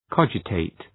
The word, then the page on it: cogitate: ‘kɒdʒə,teıt